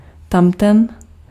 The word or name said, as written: der